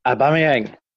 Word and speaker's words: Aubameyang: And Aubameyang of Arsenal. Excuse pronunciation. It's not me. – Yeah, you're fairly spot on there, Aubameyang. – Yeah, you're nearly there. Yeah, that's it.